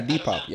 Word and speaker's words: Depop: Oh, yeah, Depop.